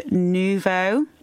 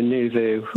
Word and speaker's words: Nous Vous: And you are currently one third of the art collective Nous Vous. That's the right way to pronounce it, is it? – No, Nous Vous. – Nous Vous, sorry.